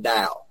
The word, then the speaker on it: Tao: In other words, and the Tao, when you hear that, it's T-A-O, but it's pronounced D-A-O, Tao, is about, is the same as your higher power.